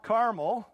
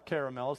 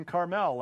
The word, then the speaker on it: Carmel: but it was actually a place called Mount Carmel, not Carmel or Caramel, some say. Some pronounce it even Carmel, like Carmel by the sea.